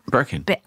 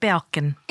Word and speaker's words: Birkin: I like your how did you pronounce Birkin? – Birkin. – I thought you said Balkin. – No. I did not say Birkin. I said Birkin.